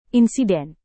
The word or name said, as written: insiden